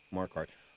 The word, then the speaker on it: Marquardt: Am I saying that right? Yeah, Nate Marquardt.